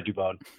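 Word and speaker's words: Dubón: Mauricio Dubon, who I pronounce it Dubon, a lot of people pronounce it Dubon, but he goes by Dubon.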